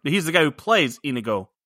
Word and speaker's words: Inigo: All right, if you're going to call me out on mispronouncing your name, Dick Monkey, it's Inigo, it's not Inigo. – Yeah? – It's not the Indigo Girls.